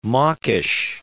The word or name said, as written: mawkish